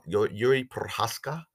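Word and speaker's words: Jiří Procházka: I think was it Yuri Perha- Jiri Prochazka.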